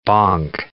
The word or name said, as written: bonk